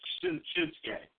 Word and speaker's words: Shinsuke: My son actually corrected me. That's what I thought it was Shinsuke. He said, no, the unit is silent, it's K, Shinsuke.